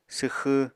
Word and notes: swxw: apatani

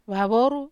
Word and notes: vavoru: Wamesa

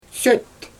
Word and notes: søt: Xyzyl

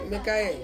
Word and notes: mekae: Ansus

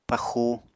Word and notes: paxu: Apatani

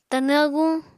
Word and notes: Tanw aguñ: Apatani

